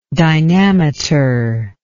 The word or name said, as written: dynameter